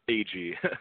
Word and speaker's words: Agee: Agee. Like the letters A-G. Oh, Agee. Okay. – Mm-hmm. A-G. Everyone mispronounces it, so that's no problem. – Agee. Yeah, just the way it looks. It's like you have a initials as a last name, but they're spelled out.